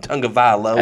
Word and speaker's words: Tagovailoa: I just like saying his name, Tagovailoa.